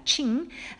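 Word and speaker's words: Qing: 칭. 아, 칭이라고 읽습니다. 중국어는.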